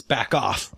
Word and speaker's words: Bachoff: It's spelled Bach. OFF. – Yeah, either one, it's a pun. – It's Back Off. – The two cellists are having a Bach off.